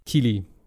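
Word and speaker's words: Keighley: Und äh, das ist auf, äh, wird auch moderiert von dem äh, Geoff Kai, Kylie, spricht man den so aus? – Ich glaube schon. Geoff, Geoff Keely? Keely, oder Keely, sowas. Keely, genau. Meine ich.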